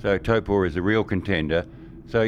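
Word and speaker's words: Taupō: Obviously, there's two circuits that you know really could work, and that's Hampton Downs, and you got the pronunciation right, Taupo.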